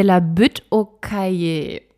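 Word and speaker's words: Butte-aux-Cailles: Als erstes möchte ich euch das Viertel Quartier de la Butte au Cahier vorstellen. Das spricht man anders aus.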